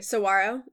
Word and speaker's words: saguaro: Saguaro. It's like the G is like a W almost, is how I've heard it pronounced, but I could be wrong too.